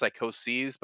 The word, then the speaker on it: psychoses: By the way, psychosis is spelled, I think it's pronounced psychoses, but so the number of psychoses, I suppose what you say, it's psych and then O-S-E-S, so...